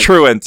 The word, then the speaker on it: truant: No, you said Tront? I thought it was truant. – It is truant.